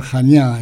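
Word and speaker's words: Jania (Χανιά): Kazantzakis nació en Candia, se pronuncia Jania en griego, en la isla de Creta en el año 1883.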